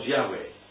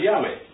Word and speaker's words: Yahweh: Okay, so that's the word Yahweh. Those capital, whenever there's capital letters, it's. What was it? Letter Yod, which is, we pronounce J, but it's pronounced Y, yeah?